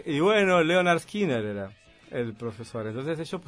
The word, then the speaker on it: Lynyrd Skynyrd: Ahí ponen cómo se pronuncia, pero se llama el pronouncer. – Claro, porque es una palabra difícil. – Es Lynyrd Skynyrd. Lynyrd Skynyrd. Ahí está fonéticamente puesto.